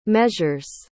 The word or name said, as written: measures